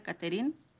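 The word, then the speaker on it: Catherine: Bueno, así se escribe, se pronuncia Caterine.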